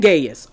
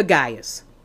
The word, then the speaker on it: Gaius: To my dear friend Gaius, or either Gaius, it's pronounced Gaius or Gaius, either way, whom I love in the truth.